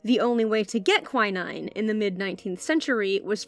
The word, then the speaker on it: quinine: He was studying under August Wilhelm von Hofmann, German chemist and professor, who was attempting to produce a synthetic version of quinine. If you're a British listener, you're probably used to hearing that word pronounced quinine. Rest assured, I'm talking about the same thing. I just have a funny accent.